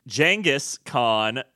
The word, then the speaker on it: Genghis Khan: Genghis Khan out there in the sporting goods store.